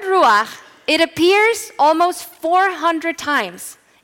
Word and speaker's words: ruach: The Hebrew word for wind is ruach. That's how you say it.